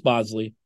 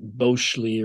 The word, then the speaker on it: Bosley: Pronounced Bosley, but spelled Bosley, which is probably why everyone calls him Boz. It helps drive home the point that it's Bosley, not Boschley or whatever it may be. He said, you know, through the family lineage, there's alternate pronunciations, but he just goes by Bosley.